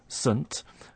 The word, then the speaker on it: Saint: First of all, instead of saying saint with a strong pronunciation, we're using the weak form, sunt.